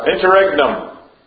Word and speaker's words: interregnum: Interim? Intergenum? Mike, you know how to pronounce that? – That's a Latin phrase. It doesn't really matter. It's an interval. – Interregnum.